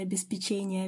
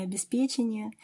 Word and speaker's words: обеспечение: что не обеспечение, а обеспечение.